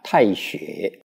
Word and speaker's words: 大学: 这个大学啊，这两个字啊，大这个字啊，在这里啊念作太。太学古字这个大跟太它是同样用这个字。